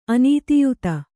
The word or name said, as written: ಅನೀತಿಯುತ